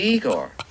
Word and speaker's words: Igor: You must be Igor. – No, it's pronounced Igor. – Igor. Igor. – But they told me it was Igor. – Well, they were wrong then, weren't they? – Attends, j'en ai des larmes.